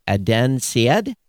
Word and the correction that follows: Adnan Syed: Adnan Syed.